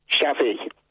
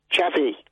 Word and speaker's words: Xavi: Shabby. – Sorry? – Shabby. – X-A-V-I. Say that, spell it again. – X-A-V-I.